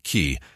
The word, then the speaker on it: cay: A cay, pronounced key, is a low-elevation sandy island formed on the surface of coral reefs.